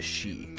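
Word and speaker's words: sídh: the Shi, which is spelled S-I-D-H, and is a common pronunciation for most Fey,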